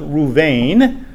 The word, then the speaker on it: Reuben: Now it comes from a compound Hebrew word, so the word is actually pronounced Reuven.